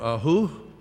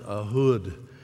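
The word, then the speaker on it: Ehud: We're going to talk about another lesser known Bible character out of the book of Judges, Ahud, or Ahu. When I first looked at the word, that's kind of how my response to it was, Ahu. But it's pronounced Ahud.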